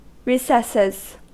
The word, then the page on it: recesses: American English